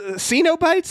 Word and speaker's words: Cenobites: Like, the Cenobites, that's how it's pronounced.